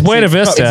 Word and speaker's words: Buena Vista: Say it right. – It's Buena Vista. – Buena Vista.